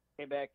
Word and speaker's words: Quebec: and I know a chick in Quebec. How do you say that? – Quebec, yeah.